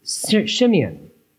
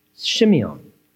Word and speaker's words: Simeon: Or if you pronounce it in the Greek, Simeon. This is different. See, Peter is not just saying his name. He's saying it in a way, the Hebrew way of pronouncing it, Simeon.